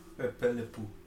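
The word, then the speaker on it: Pepe Le Pew: Eh, cancelaron a Pepe La Pi, primo. ¿O cómo se llama? Pepe Le Pú. Pepe Le Pú.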